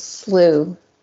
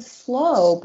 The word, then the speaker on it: slough: I just turned it on really quick in my other ear, and it's slew. – Slew? I don't like that. – I don't like that either. I could have sworn they said slow, but it's slew. – Well, it's spelled S-L-O-U-G-H.